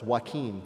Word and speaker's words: Joaquin: Joachim. Is that how you pronounce it? – Close enough. It's Joaquin. On Monday, Trevor Noah made his debut as the new host of The Blank.